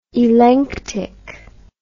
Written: elenctic

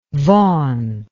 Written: Vaughn

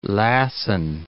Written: Lassen Peak

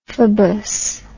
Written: phobous

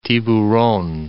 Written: Tiburon

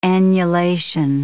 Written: annulation

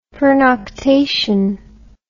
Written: pernoctation

